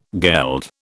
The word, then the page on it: geld: British English